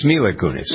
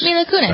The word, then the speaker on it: Mila Kunis: We got our own blah, blah here, including some gossip about Mila Kunis. Is that how you pronounce it? It's Mila Kunis. – Mila Kunis.